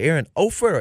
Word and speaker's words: Erin Offord: Did I say your name properly, Erin Offord or Erin Offord? – You said it correctly, Erin Offord.